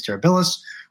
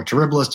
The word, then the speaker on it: terribilis: You've got Phyllobates terribilis or terribilis, depending on how you pronounce it, Phyllobates bicolor.